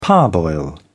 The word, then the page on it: parboil: British English